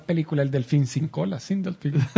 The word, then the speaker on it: Sindelfingen: Sindelfingen.